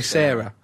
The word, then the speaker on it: Sara: You're never quite sure how to pronounce it, because Noel Gallagher's girlfriend, name is spelled S-A-R-A, but you've got to say Sarah.